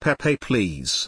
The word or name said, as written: pepepls